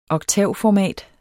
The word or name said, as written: oktavformat